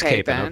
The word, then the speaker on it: Capon: It's Capon? Capon? What is it? – I say it wrong all the time. We're going Capon. It is Capon.